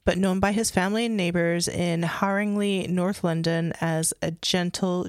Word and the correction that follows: Haringey: It was Haringey, just like what you say. – I said Lee.